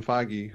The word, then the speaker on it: Feige: And, you know, basically, and you have Kevin Feige, who is, which is the correct pronunciation, by the way. It's not Feig or Feig or whatever.